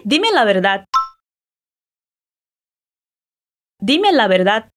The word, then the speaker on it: verdad: A primeira frase é dime la verdad, que significa me diga a verdade. Atenção à pronúncia da palavra verdade. O V tem som de B e o D no final é quase mudo.